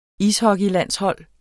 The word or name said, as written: ishockeylandshold